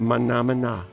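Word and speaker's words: Mah Nà Mah Nà: Mana Mana.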